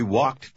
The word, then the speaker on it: walked: Помните, что «l» во втором слове не произносится. – We walked to my house.